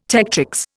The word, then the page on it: tectrix: Indian